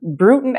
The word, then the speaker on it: Brewton: Let's go back a little to Brewton. Am I saying that right? – That's correct. – Brewton, Alabama.